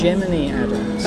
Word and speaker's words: Gemini Adams: because you were introduced to me as Gemini Adams.